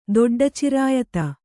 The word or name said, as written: ದೊಡ್ಡಚಿರಾಯತ